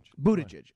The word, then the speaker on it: Buttigieg: Now, speaking of intolerance, Mayor Pete Buttigieg, am I pronouncing that correctly? Do we know? – Yes, Buttigieg. – Buttigieg, okay.